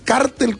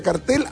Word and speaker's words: cartel: se tiende a pronunciar cártel como palabra llana y por tanto con acento, cuando significa organización ilícita o convenio entre empresas. O sea, se tiende. Pero luego agrega, la Real Academia permite las dos pronunciaciones y la escritura con y sin acento, aunque da preferencia a la pronunciación aguda y sin acento, que dice ser el uso mayoritario en el mundo hispano.